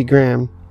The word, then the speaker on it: Grahn: So, 58 is Judy Graham. G-R-A-H-N. That's right, I pronounced it correctly.